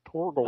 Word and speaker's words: Torgl: Yeah, I always thought it was an I, but I guess it's an L. Mark Torgol. T-O-R-G-L. Torgol.